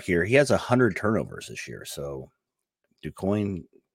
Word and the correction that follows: Duquesne: how do you say it? – Duquesne.